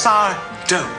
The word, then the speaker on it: Sardo: They call me Sardo. No, Mister. Accent on the do. It's Sardo.